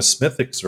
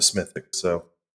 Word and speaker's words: Smithwick's: the thing I think of first is a Smithwick. So, yep, I believe it's pronounced Smithwick's. – Smithwick's? – I'm not sure, but it is delicious.